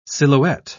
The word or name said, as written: silhouette